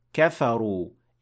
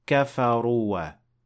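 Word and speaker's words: kafaru (كفروا): And here, kafaru ends with an alif, but you will not pronounce it. You will not say kafaruwa. That is not correct.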